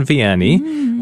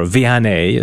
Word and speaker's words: Vianney: Tomorrow we celebrate the feast day of the patron saint of all parish priests, Saint John Vianney, or Vianney, as some people pronounce,